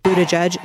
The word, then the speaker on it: Buttigieg: Buttigieg,